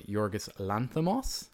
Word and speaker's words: Yorgos Lanthimos: So anyway, in case anyone hasn't worked out, we're going to talk about The Favourite, the film from Yorgos Lanthimos. How was my pronunciation? – Very good.